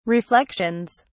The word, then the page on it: reflections: British English